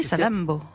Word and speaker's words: Salammbô: Salammbô. Est-ce que je le prononce bien d'ailleurs? – Oui, Salammbô. – Il y a deux M. – Il y a deux M pour bien qu'on prononce, ouais, c'est ça.